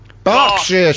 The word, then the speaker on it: Berkshire: Berkshire. He did say Berkshire. – Okay. – That's right. Where's B-E-R-K.